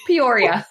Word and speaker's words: Peoria: Peoria.